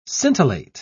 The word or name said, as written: scintillate